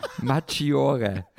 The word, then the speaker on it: Maggiore: Maggiore.